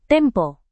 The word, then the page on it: tempo: indonesian